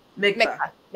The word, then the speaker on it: Mikvah: That's why you were looking at me when I was talking about the So how do I say it? Mikvah. Thank you.